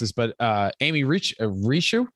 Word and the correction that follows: Rickow: I believe it's Rickow. Amy Rickow, who's